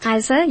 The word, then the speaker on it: Eiser: Eiser, yes.